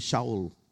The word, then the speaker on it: Saul: King Saul. King Saul or Saul? How do you pronounce? In Hindi, Shaul.